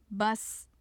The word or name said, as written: bas